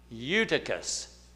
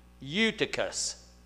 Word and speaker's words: Eutychus: Eutychus. Eutychus. Yeah, it's E-U. So if you look at it, you say, how do you say that? Just forget the E. Eutychus is how you say it.